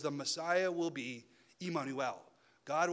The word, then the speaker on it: Immanuel: Immanuel, or as we pronounce it now, Emmanuel. When we say Emmanuel, that's just the Hebrew Immanuel.